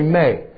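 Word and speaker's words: may (μή): You can say ou, or you can say may, m with a long e, ou may, depending upon the kind of sentence you're writing.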